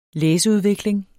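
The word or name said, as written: læseudvikling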